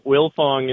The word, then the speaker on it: Wildfong: Yeah, well, it's a silent D, so yeah, Wildfong is the family name.